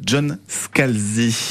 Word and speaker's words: John Scalzi: c'est un roman de science-fiction de John Scalzi.